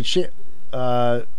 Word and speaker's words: Chantix: Chantix.